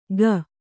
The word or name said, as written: ಗ